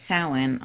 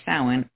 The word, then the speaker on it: Samhain: So when I was younger, I celebrated Samhain on October 31st, which is Halloween. And for witches or pagans, October 31st or Samhain, it's spelled S-A-M-H-A-I, is a high holy day in their wheel of the year.